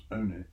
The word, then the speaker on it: Olney: And what's more, the Earl of Dartmouth gave Newton a living in the parish of Olney, usually pronounced Oney, in Bedfordshire.